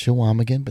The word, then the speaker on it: Chequamegon: it's Chequamegon, but that's okay. A lot of people make that mistake. It was very... – Chequamegon. – I never forgot it. Let's put it that way. – A silent Q.